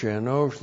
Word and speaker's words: Enosh: Enosh looks like this. Aleph, E-N, long O-S-H.